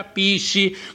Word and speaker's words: PSSI: Allora, io ho fondato il mio partito super sovranista italiano, PSSI, si pronuncia Pissi, come abbiamo sentito prima da Lino.